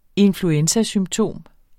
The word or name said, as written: influenzasymptom